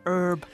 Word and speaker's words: herb: And yes, that's spelt E-R-B, and it doesn't have a dropped letter H, unlike the word that we call herb in New Zealand, but which Americans pronounce as herb.